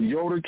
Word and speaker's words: Yoder: But anyway, Yoder is, we're talking about Yoder, Kansas. That's spelled Y-O-D-E-R, Yoder, Kansas.